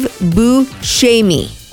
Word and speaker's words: Buscemi: And I still pronounce it wrong. It's Steve Buscemi.